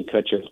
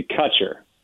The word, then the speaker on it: Kutcher: The easiest way to remember it is do the exact opposite of how you would say Ashton Kutcher's name. So it'd be Kutcher. – But it's spelled the same way. – It's spelled the same exact way, but I say Kutcher, he says Kutcher.